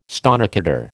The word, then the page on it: stonecutter: American English